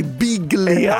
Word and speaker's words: Biglia: che si pronuncia così, Biglia.